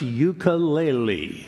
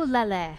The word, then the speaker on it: ukulele: Peter's first question to Taimane, how the hell do you pronounce ukulele? – Upu ukulele. – Ukulele. – Uku. – Ukulele. Lele. – You got it.